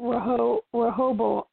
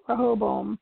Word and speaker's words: Rehoboam: It's probably because she found out about Rehoboam. I can't say it right. Rehoboam.